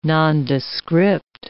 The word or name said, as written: nondescript